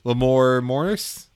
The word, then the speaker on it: Lamorne Morris: I think that especially if you get like the right actor, especially Lamorne Lamorne Morris.